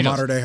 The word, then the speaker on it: Mater Dei: Bryce Young is a five star uh, quarterback, and he's at uh, I think it's called Mater Dei. I hope I pronounced that right. In yeah, Mater Dei High School.